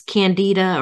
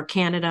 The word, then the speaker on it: candida: So it's interesting, Mary, this candida or candida, I've heard it pronounced both ways.